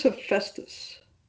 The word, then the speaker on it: Hephaestus: It says Hephaestus.